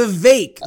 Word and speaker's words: Vivek: Vivek, uh, like cake. Vivek.